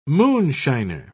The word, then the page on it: moonshiner: British English